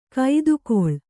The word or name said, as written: ಕೈದುಗೊಳ್